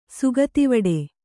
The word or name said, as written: ಸುಗತಿವಡೆ